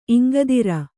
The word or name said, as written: ಇಂಗದಿರ್